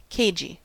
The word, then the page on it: cagey: American English